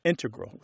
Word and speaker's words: integral: Yeah, but both correct, just to clarify here, both correct, but the mathematicians and math-using scientists are more likely to say integral with the stress on the first syllable.